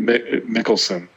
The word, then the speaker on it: Mikkelsen: And then for Thrawn, he did his voice in Rebels, but Lars Mikkelsen, am I saying that right? – Mikkelsen.